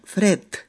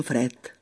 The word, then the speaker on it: fred: También tenéis que saber que la letra D, D, al final de palabra, suena, se pronuncia como la T, como la T. Por ejemplo, frío en catalán se escribe, Fred, acabado en D, pero decimos Fred, Tim Fred.